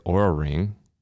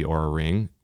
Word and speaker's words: Oura: Yeah, so the product I'm using is the Oura Ring. That's spelled O-U-R-A.